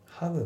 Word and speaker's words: haven't: で、あと発音なんだけど、 haven't っていうの T がね、ほぼ抜けて発音されてないですね。 Why haven't the tables? Haven't the table? じゃなくて、 haven't the table? ってなってますね。